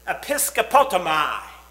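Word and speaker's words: episkopotomai (ἐπισκέπτομαι): What we're talking about there in the Greek is episkopotomai, and that is exactly the way that is pronounced.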